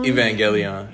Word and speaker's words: Evangelion: I can't pronounce. Oh, Evangelion? – Evangelion. – Evangelion. Yeah.